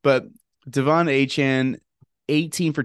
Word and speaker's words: Achane: It's not Achane. He said that his name is pronounced Achane, which good for him for telling people.